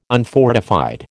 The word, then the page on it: unfortified: American English